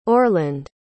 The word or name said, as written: orland